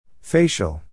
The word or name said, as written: facial